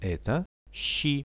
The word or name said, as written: eto shi